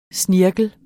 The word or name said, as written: SNIRKLEN